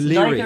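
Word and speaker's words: Lóegaire: Yeah, it's Leary. In Irish, in Old Irish, Leary.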